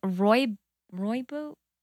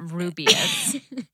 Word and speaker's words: Rooibos: No, I think it's Rooibos. I know what it. I know how it's spelled, and it's not Rubius.